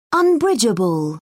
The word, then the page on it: unbridgeable: British English